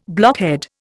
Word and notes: blockhead: Indian

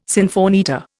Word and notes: sinfonietta: Indian

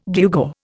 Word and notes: gewgaw: Indian

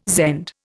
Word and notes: Zend: Indian

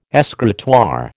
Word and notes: escritoire: American English

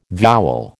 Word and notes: vowel: American English

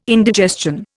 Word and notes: indigestion: Indian

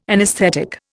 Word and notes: anaesthetic: Indian

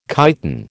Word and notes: chiton: American English

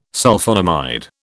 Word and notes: Sulphonamide: British English

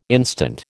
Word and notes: instant: American English

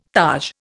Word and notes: taj: Indian

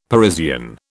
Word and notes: Parisian: British English

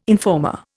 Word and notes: informer: Indian

